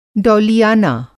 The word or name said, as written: डौलियाना